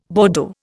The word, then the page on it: Bordeaux: Indian